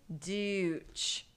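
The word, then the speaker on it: Deutch: Dooch. Dooch. It's spelled D E U TCH.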